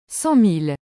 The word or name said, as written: 100000